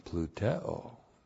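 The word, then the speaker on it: plouteo: And then now we have the verb pluteo. P-L-O-U-T-E, long O. Pluteo. Pluteo.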